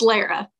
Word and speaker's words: Lara: It's Lara.